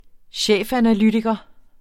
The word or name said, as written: chefanalytiker